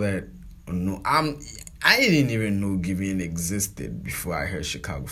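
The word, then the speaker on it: Giveon: Did I say his name right? It's Givian. Givian, yeah, the one that has Givian.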